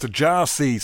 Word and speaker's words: Gyasi: It's a bit of rock and roll there from uh, a chap whose name is spelt Giassi. Uh, it's his new uh, studio album, which is actually called Pronounced Jarsi. So I presume that's what is, how you pronounce his name. In fact, uh, that's spelt G Y A S I if you're interested.